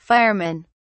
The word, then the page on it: firemen: British English